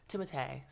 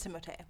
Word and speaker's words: Timothée: Timothy Stand. I can't. Timothée, sorry. – Is that how you properly say his name? – Well, as you know, I'm obsessed with Dune press tour. – Yeah. – So he was explaining in it. – It is Timothée.